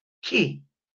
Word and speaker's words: Ch'i: Esa energía a la que ellos le nombran la energía ki. Se escribe CH apóstrofe I.